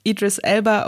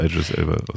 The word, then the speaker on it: Idris Elba: Ich glaube, er wird Idris Elba ausgesprochen.